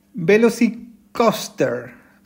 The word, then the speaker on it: VelociCoaster: Estamos hablando del Velocicoaster. Lo dije bien, Calero, ¿no? – Muy bien, muy bien.